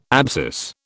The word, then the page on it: abscess: British English